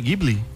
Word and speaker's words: Ghibli: A pronúncia é o quê? Ghibli? – Ghibli, é. – Ghibli, né? – É.